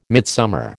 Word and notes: midsummer: American English

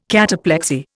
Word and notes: cataplexy: Indian

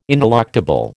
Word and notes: ineluctable: American English

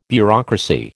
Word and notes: bureaucracy: American English

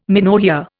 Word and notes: menorrhoea: Indian

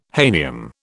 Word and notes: hahnium: British English

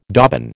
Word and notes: dobbin: American English

